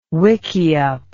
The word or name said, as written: wikiup